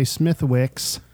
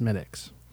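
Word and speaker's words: Smithwick's: You can come in, you can say Smithwicks... – Once. – One time. Okay, and then we properly and politely tell you it's actually pronounced Smittics.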